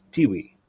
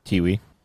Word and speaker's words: Tiwi: And the one that they eventually settled on was, I think it's pronounced Tiwi. – Tiwi. – Tiwi, as filmed.